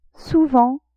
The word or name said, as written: souvent